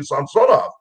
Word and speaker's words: Samsonov: And when he came to our team, I said, Sergei, I've been calling you Samsonov all these years. He says, well, you've been pronouncing it right.